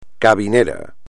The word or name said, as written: cabinera